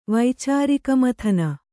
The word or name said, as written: ವೈಚಾರಿಕಮಥನ